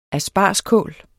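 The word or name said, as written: aspargeskål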